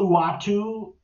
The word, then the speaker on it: Uatu: Until Uatu, as that's how you pronounce it, shows up and it's Jeffrey Wright as a big bald man in live action, it's not important.